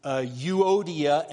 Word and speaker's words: Euodia: The best pronunciation I've come up with is Euodia and Syntyche.